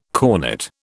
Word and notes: cornett: British English

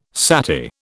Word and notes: sati: British English

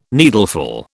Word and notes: needleful: British English

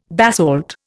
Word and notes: basalt: Indian

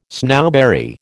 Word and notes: snowberry: American English

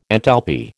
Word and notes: enthalpy: American English

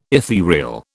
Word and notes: etherial: British English

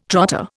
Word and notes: trotter: Indian

